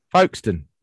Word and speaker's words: Folkestone: Folkestone.